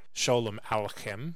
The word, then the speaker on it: Sholem Aleichem: written by Sholem Aleichem, which I believe I'm pronouncing that correctly, but hopefully I did look it up.